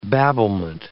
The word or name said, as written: babblement